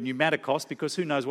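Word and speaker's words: pneumatikos (πνευματικός): That's how you pronounce that word, pneumatikos.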